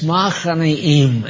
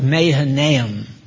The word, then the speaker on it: Mahanaim: In the Hebrew, it's Machanaim, and it comes across, it's spelled Mahanaim.